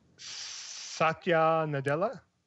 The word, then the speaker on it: Satya Nadella: Satya Nadella.